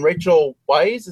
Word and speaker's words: Rachel Weisz: It stars John Cusack, Gene Hackman, Dustin Hoffman, Rachel Weisz.